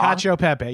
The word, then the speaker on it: cacio e pepe: I feel like cacio e pepe, I'm sure I'm saying that so wrong. – Cacio pepe.